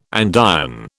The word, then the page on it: andiron: British English